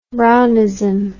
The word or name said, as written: Brownist